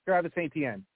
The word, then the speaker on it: Travis Etienne: So my question is, should I focus on so there's Javonte Williams, Travis Etienne, I don't know if I'm saying that right, the guy from Clemson, and then the Najee Harris. So those three guys. – Travis Etienne.